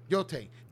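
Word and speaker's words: Yōtei: Yotei.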